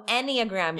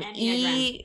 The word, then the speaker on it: Enneagram: I thought you said anagram, and I was like, yeah, that's like... – It's like, like witches. – You know what I mean? No, no. – No, Enneagram. Enneagram. How do you spell it? – E-N-N-E-G-R-A-M. – E-N-N-E-A-G-R-A-M.